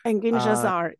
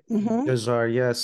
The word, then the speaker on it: Engin Cezar: I'm particularly interested in, how about I'm pronouncing the name correctly, Engin Cezar, right? – Engin Cezar. – Cezar, yes.